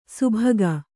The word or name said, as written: ಸುಭಗ